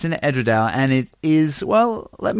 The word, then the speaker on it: Edradour: Well, I've always called it Edradour, and I might be saying it wrong, but you got any suggestions, Ted? – Edradour. – Edradour. – Edradour. – Yeah, I'm going to call it Edradour from now on.